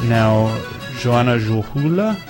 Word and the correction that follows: Juhola: Dang, I always say her name wrong. – Joanna about right. – Juhola. I get the U and the O mixed up, and I don't speak Finnish anyways, so I don't know.